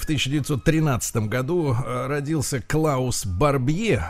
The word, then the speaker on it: Барбье: Э, фамилия у него пишется так же, как Барби. Угу понимаете, да? То есть э, у вас сначала вот То Барбье. Клаус Барби. – Клаус Барби, но на самом деле Барбье это жуткий человек.